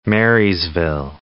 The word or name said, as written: Marysville